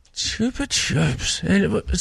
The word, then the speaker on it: Chupa Chups: Chubba Chubs. – You're Chupa Chup Pop without this. – Chupa Chups.